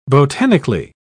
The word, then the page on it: botanically: British English